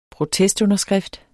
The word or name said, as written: protestunderskrift